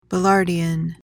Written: ballardian

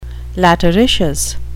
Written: lateritious

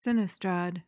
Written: sinistrad

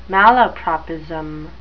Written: malapropism

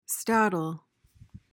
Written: staddle

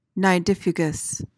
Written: nidifugous

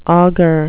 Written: auger

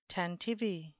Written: tantivy

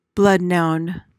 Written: bloodnoun

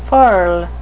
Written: furl

